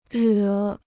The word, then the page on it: 惹: rě